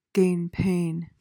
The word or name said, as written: gainpain